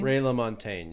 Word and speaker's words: Ray LaMontagne: Is that how you pronounce his name? – Ray LaMontagne, yeah. – Yeah. – That's how I say it.